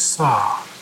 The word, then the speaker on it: Sau: As you breathe in, I'd like you to mentally chant the word Hum, as in Hong Kong, H-O-N-G. And as you breathe out, I would like you to mentally chant the word saw, like hammer and saw, only it's spelled S-A-U.